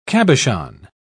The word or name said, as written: cabochon